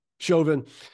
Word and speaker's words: Chauvin: Derek, how do you say his name? – Chauvin.